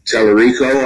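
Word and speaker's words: Talarico: And of course, down in Texas, one of the candidates down there, James Tellerico, I think, is that correct? – That is how he pronounces it.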